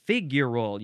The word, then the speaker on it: figural: Figural. You got to emphasize the first syllable. Figural. With an A-L at the end.